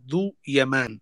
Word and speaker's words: ذو يمان: فيقول الدكتور يوسف محمد عبد الله إنه تم ذكر الزاي محل الذال، محل إيش؟ محل الذال. فلهذا نقول عنه أبرهة ذو يمان، أي أبرهة اليماني.